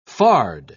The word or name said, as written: fard